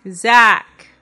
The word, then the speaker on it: Zach: Zach. Rhymes with? – Black.